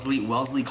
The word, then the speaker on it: Wellesley: Wellesley College, and then you just switched to MIT.